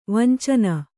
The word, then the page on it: ವಂಚನೆ: vancane